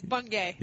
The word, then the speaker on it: Bungay: We're just going to, it's Bungay. – You say it like you see it.